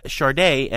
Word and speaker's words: Sade: Sade, and that is how you pronounce her name. I know it is spelled S-A-D-E, but she pronounces it Sade, was being positioned by the recording industry as the next Susan Boyle-like hit.